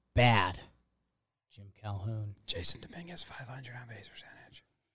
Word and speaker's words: Jasson Dominguez: Jasson Dominguez.